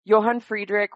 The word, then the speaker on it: Johann Friedrich: Tell us a bit about Sibylla, and is it Johann Friedrich? – Yes, so in English, Sibylla and John Frederick, Johann Friedrich.